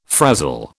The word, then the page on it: frazil: British English